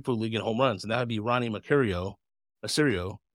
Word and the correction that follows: Mauricio: Is it Mercurio? Did I say that right correctly? What Mauricio? Mauricio, thank you. Yes, I knew I was saying it wrong.